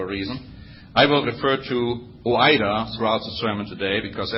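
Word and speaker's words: oida (οἶδα): And that word is spelled, according to some commentators, oida. Let me spell that one for you. O-I-D-A. O-I-D-A.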